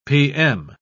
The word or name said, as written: pm